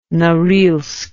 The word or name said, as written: Norilsk